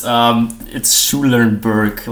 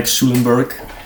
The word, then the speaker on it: Schulenberg: It's Schulenberg, like the school, and the Berg, and the hill, like Schulenberg.